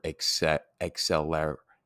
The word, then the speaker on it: acceleratio: Acceleration, the term acceleration comes from the Latin word acceleratio, acceleratio. A little bit different. Those C's, remember, are hard C's. They're pronounced as K's. So that's why acceleratio.